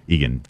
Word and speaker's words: Eagan: Eagan. Did I say that right? Yeah. – That's right.